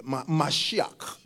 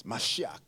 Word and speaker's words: Mashiach: I wish I could say it well in Hebrew. But Mashiach. But they say it, Mashiach. Yes, Mashiach. Yeah, I think you did pretty good. Hallelujah. So that is how it's pronounced.